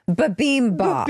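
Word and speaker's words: bibimbap: I'm going to show all our YouTubers the bimbap. – You don't say that correctly. – That, excuse me. Babimbap.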